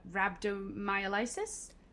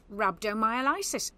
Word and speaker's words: rhabdomyolysis: So yes, I will name it, but I think you might have to correct my pronunciation. But rhabdomyolysis? – It was perfect. Rhabdomyolysis.